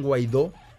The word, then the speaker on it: Guaidó: Es con G-U-A-I-D-O, acento en la O, Juan Guaidó.